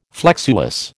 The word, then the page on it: flexuous: American English